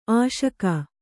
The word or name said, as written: ಅಶೋಕ